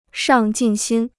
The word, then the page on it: 上进心: shàng jìn xīn